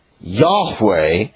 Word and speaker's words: Yahweh: And the best that we do, I mean, it's very little guesswork, actually, is the last vowel sound, A. So, Yahweh, this is his name.